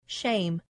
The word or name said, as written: shame